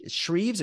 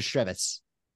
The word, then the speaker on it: Shreves: The waltz of the Sh- Shreves or Shrevets? – Shrevs. I've, Shreves? always pronounced it as Shreves, but you know they're Canadian.